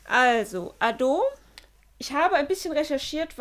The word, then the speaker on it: Adou: Denn der Titel heißt Ado. Geschrieben wird er aber Ado-U. Also A-D-O-U. Spricht man aber im Japanischen nicht aus, sondern O-U ist ein langes U.